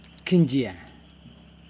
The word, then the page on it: kindJije: Yokoim